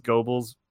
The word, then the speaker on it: Goebbels: which is not right. Also, it's Goebbels. It's a fun German pronunciation. It's okay. Uh, which sounds like gerbil in English. If gerbil were pronounced with a hard G sound.